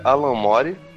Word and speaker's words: Alan Moore: É, para começar é Alan Moore Pior que eu achava que era zoeira das pessoas zoando o cara, mas realmente é a pronúncia certa.